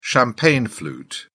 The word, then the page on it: champagne flute: British English